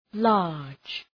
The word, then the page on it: large: lɑ:rdʒ